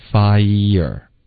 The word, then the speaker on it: fire: Okay, fire 的这个发音是刚刚发成 fair，我们要发一。所以是发一的一个滑动 fire。